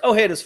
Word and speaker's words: Ojeda: So when we come back, Richard Ojeda, am I saying it correctly? Close. – Yeah, that works. Ojeda's fine.